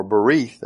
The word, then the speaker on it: berith: The word for covenant is the word berith, or berith, actually how it's pronounced.